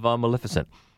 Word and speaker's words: Maleficent: and maybe you love Maleficent. And I keep saying it correctly. – You do.